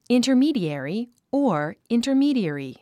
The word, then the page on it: intermediary: ìntə r míːdièri; インタァミーディアリィ